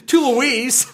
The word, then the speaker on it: Toulouse: Honestly, you know, Touloua or whatever you pronounce it, definitely hack that up. Toulouse.